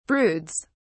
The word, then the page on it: broods: British English